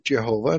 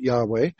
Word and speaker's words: YHWH: Most of us pronounce it Jehovah. There are some that pronounce it Yahweh.